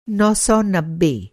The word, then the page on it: नौ सौ नब्बे: Hindi